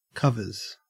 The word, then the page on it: covers: Australian English